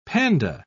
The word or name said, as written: panda